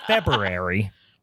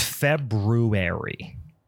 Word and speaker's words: February: Fe- do you guys say Feb? It's not February, it's February. All right. February. No, not February. February.